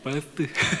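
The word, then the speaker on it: birth: 예, 벌뜨입니다. TH 발음. 괜찮나요? 벌뜨.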